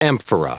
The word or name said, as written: amphora